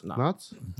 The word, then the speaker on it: Knott's: You just said Knott's. – Bro, that's how they spell it. K-N-O-T. – Yeah, but do you say Iceland? No, you say Island.